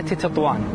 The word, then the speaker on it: تطوان: هذه المدينة العريقة، العريقة بتاريخها والعريقة أيضا بجغرافيتها، فهي مدينة قديمة والناس ينطقونها قديما وحديثا نطقين مختلفين، إما بتطوان وإما بتطاون، وكلاهما منطوق به قديما.